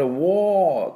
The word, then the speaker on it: war: この war はですね、まあ、昨日アメリカ発音一辺倒でやりましたが、これ war って r 最後 war war って伸ばして r で締めるのがアメリカ。